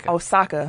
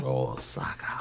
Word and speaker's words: Osaka: And Naomi Asuka. I always say her name like the wrestler. – Osaka. – Osaka. – It's like the city in Japan.